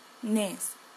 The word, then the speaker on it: calcetines: Cal. Se. Ti. Calcetines. Calcetines. Calcetines. Okay? Calcetines it's, it is one of the names of socks in Spanish.